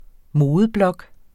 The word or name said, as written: modeblog